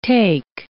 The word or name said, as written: take